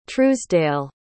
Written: truesdale

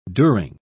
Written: during

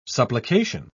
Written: supplication

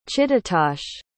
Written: chittatosh

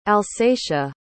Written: alsatia

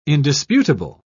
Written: indisputable

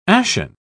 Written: ashen

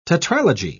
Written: tetralogy

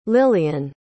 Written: lilian

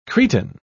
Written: cretin

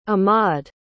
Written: ahmaud

